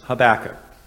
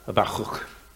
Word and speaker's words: Habakkuk: The Hebrew pronunciation of his name would be something like Habakkuk. Yeah, that's exactly how it sounded. If you have a cold, it's easier to say, because it's a Habuk. But we are going to use the English pronunciation for the same reason that my son is David and not David, which would be more the Hebrew way to do it.